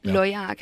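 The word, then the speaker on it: LOYAC: We should spell the acronym here. It's L-O-Y-A-C. – Yeah. – We pronounce that Loyak, is it? – Yeah, Loyak.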